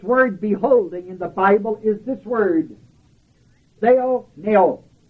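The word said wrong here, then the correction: Theoreo (θεωρέω): And that word is spelled theoreo. This is an R. Theoreo.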